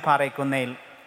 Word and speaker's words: Parekunnel: See, I am Father Bibin Parekunnel, and I thank B for pronouncing my second name. And I'm surprised. She was singing like, Parekunnel. So I was so happy about it.